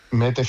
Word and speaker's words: Mette: Mette mete Frederiksen.